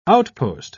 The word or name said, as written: outpost